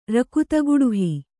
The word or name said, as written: ರಕುತಗುಡುಹಿ